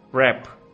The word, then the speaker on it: wrap: அடுத்ததாக ரேப். இதில் நீங்கள் கவனத்தில் கொள்ள வேண்டிய விடயம் என்னவென்றால், ரெப், WRAP, இந்த சொல்லிலே W என்பது சைலண்ட் லெட்டர், அதாவது உச்சரிக்கப்பட மாட்டாது.